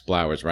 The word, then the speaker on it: Blowers: Well, in my freshman year of high school, I ran for class president just so I could wear buttons that said Max Blowers rhymes with flowers.